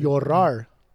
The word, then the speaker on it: Llorar: They put out a new song called Llorar. I don't know how to pronounce it. – Llorar.